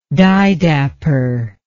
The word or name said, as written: didapper